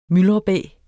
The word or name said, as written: myldrebæ